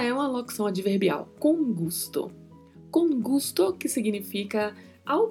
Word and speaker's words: gusto: Na pronúncia, a letra O é mais fechada, então falamos gusto, gusto.